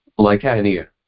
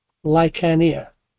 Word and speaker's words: Lycaonia: Lycaonia. – Lycaonia.